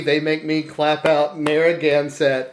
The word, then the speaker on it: Narragansett: walked over, and was like, "Listen, Narragansett." And I'm like, "Oh, okay." She's like, "No, do it with me." So I had to stand there at the bar and clap out the syllables.